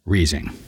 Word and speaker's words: Rising: spelled like the rising sun, but apparently pronounced Riesing.